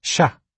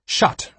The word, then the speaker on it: chat: It had a picture of a cat with the word chat underneath, spelled C-H-A-T, and it played a recording of the word. Chat, said the recording, since the T at the end of chat is silent. Shot, repeated my friend. No, it's chat, I corrected.